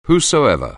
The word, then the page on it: whosoever: anglais